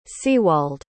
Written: sewald

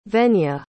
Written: venya

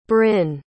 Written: brin

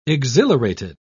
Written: exhilarated

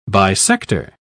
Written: bisector

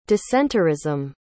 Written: dissenterism

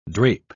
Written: drape

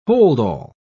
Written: holdall

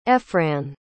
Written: efran

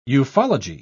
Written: ufology

